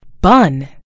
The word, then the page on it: bun: British English